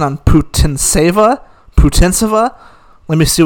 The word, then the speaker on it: Putintseva: Hi, my name is Yulia Putintseva. Putintseva. – Okay, so Putintseva beat Risk Arbitrage.